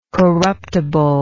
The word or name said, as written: corruptibly